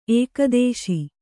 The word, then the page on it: ಏಕಾದಶ: kannada